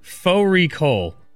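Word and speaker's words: fårikål: Fori Kol, which is a mutton and cabbage. It's spelt like Fari Kol.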